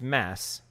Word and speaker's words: Mads: I, I think Mads was a great. world. I sorry, it's not Mads. It's Mass. Mass. Mass. You don't pronounce the D.